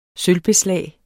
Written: sølvbeslag

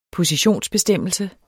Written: position

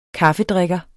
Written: kaffedrikker

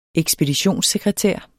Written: ekspeditionssekretær

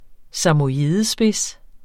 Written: samojedespids

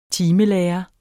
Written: timelærer

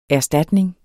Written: erstatning

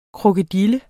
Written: Crocodylia

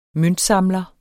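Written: MØNTSAMLER

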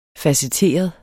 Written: facetteret

